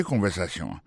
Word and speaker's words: conversation: Écoutez conversation. Mot conversation, accent tombé sur avant-dernier syllabe là.